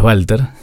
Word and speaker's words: Walter: Sí, soy Si me preguntaban, sí, no, y además, mamá, mi apellido es Walter. Sí. Vos lo pronunciaste muy bien. Eh, Porque se escribe W, igual que el nombre, Walter pero bueno, es alemán el apellido